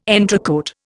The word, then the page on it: entrecôte: Indian